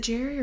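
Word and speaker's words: Gerry: Is it Jerry or Gary? – Yeah, Jerry. – Jerry. I don't like that it's spelled with a G.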